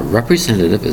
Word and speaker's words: representative: Now, representative is an important word for government and many types of groups. That's right. The word representative has five syllables. Representative. Spelled R-E-P-R-E-S-E-N-T-A-T-I-V-E. Again, five syllables. The word is representative, spelled R-E-P-R-E-S-E-N-T-A-T-I-V-E.